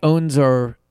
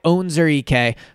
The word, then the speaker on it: Onwuzurike: So the first is Levi Onwuzurike. I looked that up. That's how you pronounce it. Levi Onwuzurike from Detroit.